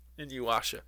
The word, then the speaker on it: Inuyasha: I know how to pronounce it. Or Black sure. Butler. Oh, it's an anime. Inuyasha. – Inuyasha.